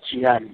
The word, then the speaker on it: Jiyane: Yeah, Shimmy Jiyane.